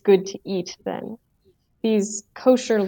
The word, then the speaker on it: kosher: What is kosher? – Yes, so kosher is the Ashkenazi or the European pronunciation of the word, the Hebrew word kasher, which means to be fit.